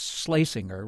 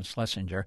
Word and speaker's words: Schlesinger: I'm Alex Chadwick, the historian Arthur Schlesinger. And we checked, that is the pronunciation, it's Schlesinger rather than Schlesinger.